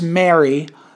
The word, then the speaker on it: Mary: So it's like um, many people I've met, like Eva and her friend Kathy's from California, they pronounce Mary the same way that they pronounce um, like Merry Christmas. And they pronounce Mary and Merry the same way that they pronounce holy matrimony, which is Mary.